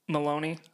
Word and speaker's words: Meloni: Meloni. M-E-L-O-I. – Gotcha. Rhymes with baloney, so fuck her.